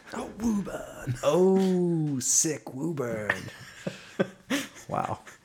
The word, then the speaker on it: Woburn: I would pronounce that Woburn. I always, yeah. It's always weird when... – W-O-B-U-R-N. Yeah. Woburn.